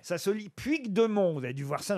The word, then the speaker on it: Puigdemont: Et on doit prononcer Puigdemont, en fait.